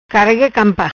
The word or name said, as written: Campa Carrega